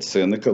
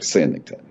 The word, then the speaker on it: Сенека: Давайте будем, я буду называть Сенека, вы как хотите, товарищи. Даже Википедия ставит два ударения. Да. А грамота настаивает на, а, на ударении на второй слог, а, привлекая мощного свидетеля Александра Сергеевича Пушкина.